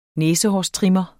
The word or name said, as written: næsehårstrimmer